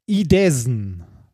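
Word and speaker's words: Idåsen: Er heißt Idesen. Idesen? Idesen. Also ein A mit so einem, also so ein, so ein Angström-A. das äh, spricht man, glaube ich, O aus.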